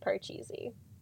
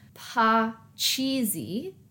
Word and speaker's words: Parcheesi: It is derived from the Indian game Parcheesi. – Okay. – Which I pronounced that way just to really make it clear. It's not Parcheesi, it's Parcheesi.